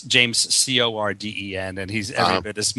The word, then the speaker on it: Corden: It's James Corden, not Carden.